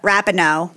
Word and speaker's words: Rapinoe: Rapinoe.